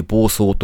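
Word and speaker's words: 防草: 草を防ぐと書いて暴走と読むんだと思うんですが、そのロボット開発がですね、各国で進んでいるんだそうです。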